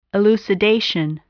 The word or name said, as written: elucidation